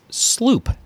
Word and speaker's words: sloop: This would be the Dutch word sloep, but it's pronounced sloop, S-L-O-O-P, sloop.